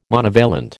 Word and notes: monovalent: American English

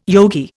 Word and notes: yogi: Indian

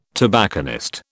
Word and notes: tobacconist: British English

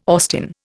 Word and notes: Austin: Indian

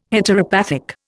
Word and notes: Heteropathic: Indian

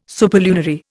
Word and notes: superlunary: Indian

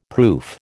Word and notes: proof: American English

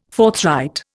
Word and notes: forthright: Indian

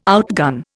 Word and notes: outgun: Indian

